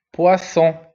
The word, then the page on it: poisson: son wa